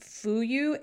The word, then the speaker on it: Fuyu: I believe it's pronounced Fuyu. It's F-U-Y-U.